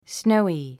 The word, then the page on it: snowy: snóui; スノォゥイ